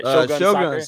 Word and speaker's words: Shogun: Shotgun Soccer? Did I say it right? – Shogun Soccer.